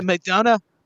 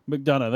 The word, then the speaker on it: McDonough: Is it McDonough? – McDonough, there you go. McDonough, what am I on about McDonough, I'm trying to say.